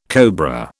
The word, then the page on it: cobra: British English